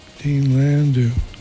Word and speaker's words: Dean Landau: I always think I pronounce it correctly. How is it again? – Dean Landau.